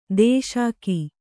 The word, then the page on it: ದೂಷಿಕೆ: dūṣike